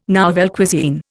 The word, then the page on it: nouvelle cuisine: Indian